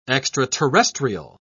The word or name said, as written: extraterrestrial